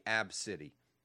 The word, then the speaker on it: ABCDE: It's supposed to be pronounced phonetically Ab-City.